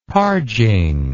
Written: parging

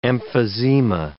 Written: emphysema

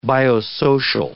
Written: biosocial

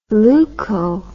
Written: leuko

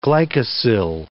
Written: glycosyl

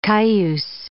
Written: Cayuse

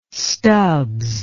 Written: Stubbs

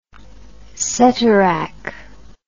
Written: ceterach

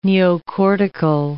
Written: neocortical

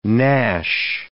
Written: gnash

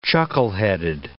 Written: chuckleheaded

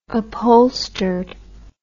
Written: upholstered

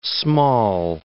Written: small

